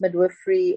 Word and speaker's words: midwifery: Is that how you pronounce it? Midwifery. Have I said it wrong? – Midwifery. It's fine. – I don't know. I was like, I was surprised. – Yes, it's midwifery.